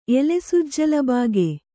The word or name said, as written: ಎಲೆಸುಜ್ಜಲಬಾಗೆ